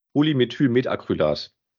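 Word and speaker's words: Polymethylmethacrylat: Habe ich es richtig ausgesprochen? Polymethylmetacrylat. – Genau das hatte ich gesagt, jawohl.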